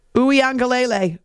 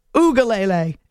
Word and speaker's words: Uyangalele: Earlier when I was saying it, I was going with Ugalele. And I was missing the N.